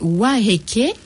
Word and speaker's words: Waiheke: Tout le monde dit prononce mal. Donc c'est Waiheke.